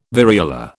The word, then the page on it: variola: British English